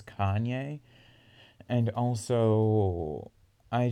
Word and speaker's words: Kanye: Oh, Wikipedia gives a pronunciation for Kanye. It's Kanye.